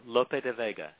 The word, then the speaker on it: Lope de Vega: You call Lope, how do I pronounce, Lope de Vega? – Yeah, Lope de Vega. Yeah, good.